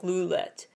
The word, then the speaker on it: Lullet: Their fandom, Lullet, which is supposed to be pronounced actually more like roulette, like lulette.